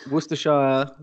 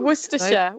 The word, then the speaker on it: Worcestershire: And this is where Worcestershire, did I say it right? Source Nearly. – Comes from? – Worcestershire. Right? Worcestershire.